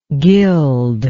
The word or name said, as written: gildable